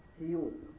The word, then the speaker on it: سيوط: الإمام السيوطي لعلكم يعني لمحتم من خلال تسميته أنه من نصر ومن أسيوط، وتنطق سيوط أيضا بغير همز في أولها كما حققه يقود في معجم البلدان، يعني كلاهما سواء إن قلت أسيوط أو قلت سيوط.